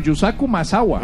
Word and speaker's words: Yusaku Maezawa: Yukazu Maezawa. No, perdón, Yusaku Maezawa. – Ah. – Perdón, lo dije mal.